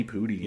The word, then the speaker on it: Pudi: Danny Pudi. – Danny Pudi, yeah. I think is how you pronounce it.